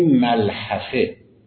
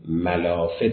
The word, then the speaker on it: ملحفه: کلمه ملحفه که ما توی فارسی ملافه تلفظ میکنیم، از همین ریشه گرفته شده، ملحفه، چون یک پوششی است که روی تمام بدن را فرا میگیرد. میگن ملحفه. ملحفه پس اصلش عربیه، ما توی فارسی میگیم ملافه، چون تمام بدن را میپوشانه.